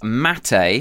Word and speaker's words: mate: Okay, it is mate. I believe that's the correct pronunciation.